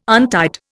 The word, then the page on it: untied: Indian